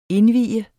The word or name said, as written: indvie